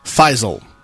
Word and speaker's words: Faisal: From Faisal, pronounced like Fievel in an American tale, Fievel Goes West, but with a Z, means stubborn in Arabic.